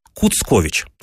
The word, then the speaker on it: Куцкович: А именно, что это отчество, получается, этого персонажа Кучкович пишется через букву «С». Куцкович. Казалось бы, это ошибка, но нет. Дело в том, что такое произношение, цоканье, было свойственно для новгородского говора.